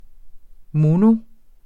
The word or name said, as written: mono